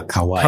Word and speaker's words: Kauai: Jetzt habe ich das richtig ausgesprochen? – Ich glaube ja, Kauai. – Ne? Kauai. Ne? Mhm.